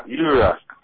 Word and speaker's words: Yurok: I'm talking to the filmmaker of Yurok. Did I pronounce that right? – Yeah, Yurok.